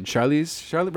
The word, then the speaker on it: Charlize: Charlize, right? I'm saying it right. Yes, so The Old Guard with Charlize Theron.